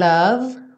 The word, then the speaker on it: love: You say, one love, one life. Aqui sempre prestando atenção que as palavras one, love, life, todas elas terminam com E, mas essa letra E não é pronunciada.